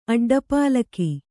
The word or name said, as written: ಅಡ್ಡಪಾಲಕಿ